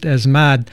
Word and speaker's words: ESMAD: And to give some idea of the repression that Alberto is describing by the riot police, and their initials in Spanish are ESMAD, pronounced ESMAD.